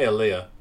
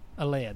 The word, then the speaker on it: Aaliyah: So number 1 was More Than a Woman with, is it Aaliyah? How do you say her name? – I say Aaliyah. – Aaliyah, there you go.